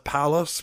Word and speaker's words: Pallas: What, the bust of Pallas, because it, and got it to rhyme with phallus.